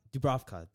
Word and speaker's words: Dubravka: Dubravka? – Dubravka, there we go.